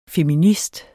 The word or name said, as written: FEMINISTER